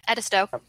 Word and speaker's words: Edisto: Was that pronounced Edisto? – Yeah, Edisto.